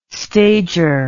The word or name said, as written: stager